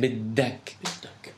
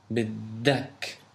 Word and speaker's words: بدك: No, بدك. – There's a little emphasis on the dal. بدك.